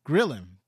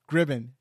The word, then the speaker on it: Gribben: I want to thank our special guest, Toby Grillin. Am I saying that right? – Gribben. – Gribben.